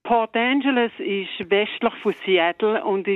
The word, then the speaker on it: Port Angeles: Port Angeles, habe ich es richtig gesagt? – Ja, das ist richtig.